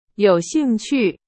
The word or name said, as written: 兴趣